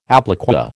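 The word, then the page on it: appliqué: American English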